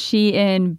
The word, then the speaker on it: Shein: It is pronounced Shein because it is a Chinese company. – Okay. – And so Shein is a Chinese word.